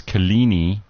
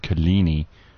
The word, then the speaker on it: Coligny: Apparently you pronounce Kalini, C-O-L-I-G-N-Y, you pronounce it Kalini, and that's in the northwest.